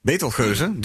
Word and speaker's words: Betelgeuze: Hoe spreek je die in het Nederlands uit? – Betelgeuze, denk ik. – Betelgeuze? – Ja. – Oké, cool. Betelgeuze, dus.